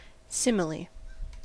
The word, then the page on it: simile: American English